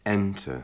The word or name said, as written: enter